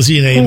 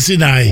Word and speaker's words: Sinai: Nel mondo noi diciamo Sinai, ma dovrebbe pronunciarsi Sinai.